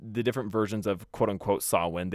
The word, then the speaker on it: Samhain: So Samhain, pronounced Samhain.